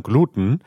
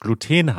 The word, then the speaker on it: Gluten: Mit langem E, Gluten, wie in zudem, oder mit kurzem E, wie in Sputen.